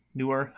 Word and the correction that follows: Neuer: How do you pronounce his last name? The Germany goalie. Neuer, thank you.